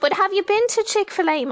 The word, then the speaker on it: Chick-fil-A: I think Americans pronounce it Chick-fil-A, would I be right? – You've nailed it, and I'm not surprised you've nailed it.